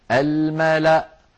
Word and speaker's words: الملأ: فتوصل هذه الكلمة الملأ إني، ويوقف عليها بهمزة ساكنة الملأ الملأ هكذا، ولا تنطق الواو التي هي كرسي للهمزة، ولا تنطق الألف التي بعدها.